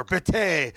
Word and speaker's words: Bête: I'm assuming that I pronounced Bête right. Or Bête. – Bête. – Le Belle. – Like Bête.